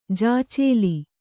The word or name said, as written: ಜಾಚೇಲಿ